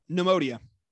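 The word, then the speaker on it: Neimoidia: Oh, Neemodia.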